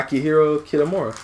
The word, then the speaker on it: Akihiro Kitamura: Is that how you say his name? – Yeah, Akihiro Kitamura.